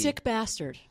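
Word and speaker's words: SCKBSTD: Well, how do you say the title of that? – Oh, my gosh. – It's S-C-K-B-S-T-D. – It's Sick Bastard.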